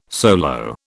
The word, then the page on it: solo: British English